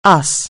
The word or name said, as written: US